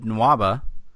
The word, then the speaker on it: Nwaba: And we are going to talk about David Nwaba.